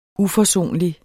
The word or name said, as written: UFORSONLIGT